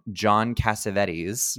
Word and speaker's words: Cassavetes: But then you find out that it's directed by, I'm going to always pronounce this so wrong, John Cassavetes.